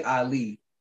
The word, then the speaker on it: AHHLEE: So it's Roy AHHLEE, and it's spelled R-O-Y, and then AHHLEE is A-H-H-L-E-E.